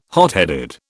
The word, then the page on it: hotheaded: British English